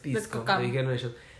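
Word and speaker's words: VSCO: no se dice Vesco, se dice Visco.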